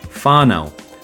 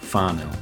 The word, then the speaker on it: whānau: Whānau, that is the extended family and community. Whānau.